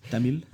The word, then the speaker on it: Tamil: You probably said that better than I usually say. I say it like I'm from St. Louis. – Tamil. – Yeah, that's pretty good. There's like a Tamil, is how people say it, and I never ever say it.